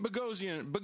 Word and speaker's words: Boghossian: We have Pete Boghossian. Sorry, Pete. He explained to us earlier, listeners, that it rhymes with explosion.